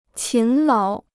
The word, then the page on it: 勤劳: qín láo